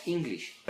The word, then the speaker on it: English: Entonces, inglés en inglés escribe con E, English, pero se pronuncia English. La E siempre va a ser llamada I, y la letra I latina, como dices, si nos unimos el puntito pegando el palito, ¡ay!